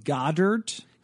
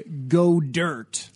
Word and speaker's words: Goedert: I have been told that it is pronounced Goddard. Because I wanted it to be go dirt.